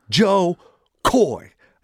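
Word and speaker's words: Koy: I go, it's just Coy, man. Joe Coy. All right, Joe Coy.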